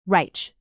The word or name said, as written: reich